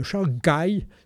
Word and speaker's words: Chancay: Alors je prononce son nom parce que ça s'appelle le port de Shanghai. Ça ressemble à Shanghai, mais ça s'écrit Chancay.